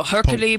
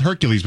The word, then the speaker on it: Hercule: Hercule Poirot, right? – Not Hercules, though. It's spelled Hercules, but it's Hercule Poirot.